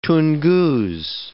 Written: Tungus

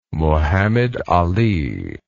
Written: Mohammed Ali

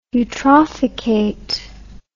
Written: eutrophicate

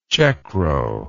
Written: checkrow

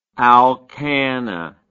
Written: alkanna

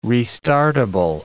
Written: restartable

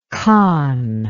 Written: Kahn

